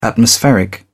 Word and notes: atmospheric: British English

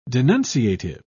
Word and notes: denunciative: British English

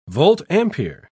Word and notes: volt-ampere: British English

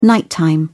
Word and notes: nighttime: British English

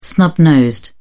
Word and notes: snub nosed: British English

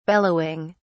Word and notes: bellowing: British English

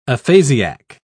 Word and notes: aphasiac: British English

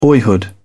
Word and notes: boyhood: British English

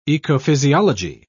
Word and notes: ecophysiology: British English

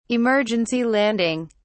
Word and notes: emergency landing: British English

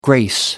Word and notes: grace: American English

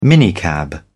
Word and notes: minicab: British English

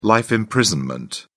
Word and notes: life imprisonment: British English

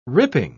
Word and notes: ripping: British English

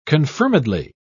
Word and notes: confirmedly: British English

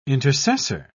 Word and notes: intercessor: British English

